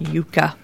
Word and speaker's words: Yuka: Yuka, yep. – And it's spelled Y-U-K-A, and...